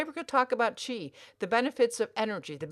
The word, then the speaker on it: Qi: Yeah, that's how you pronounce it. Yes, you're correct. Qi.